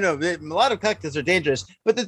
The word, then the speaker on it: Molotov: It's like mullet and a little bit of top. Molotov.